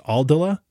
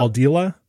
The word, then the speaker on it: Aldila: All right, going to pre 2005 for driver, my choice was the Ping G2, and this has an I, I think it's pronounced Aldila yep. Aldila, Aldila uh, stock shaft, with, which is a good stock shaft.